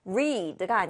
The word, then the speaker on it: read: 읽다는 주의하셔야 할 게 과거형일 때는 발음이 달라요. read가 아니라 read라고 읽으셔야 됩니다.